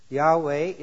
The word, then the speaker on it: Yahweh: Yahweh is what most scholars today feel is the correct pronunciation of the name.